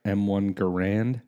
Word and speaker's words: M1 Garand: Am I saying that right? M1 Garand? – Yeah, absolutely.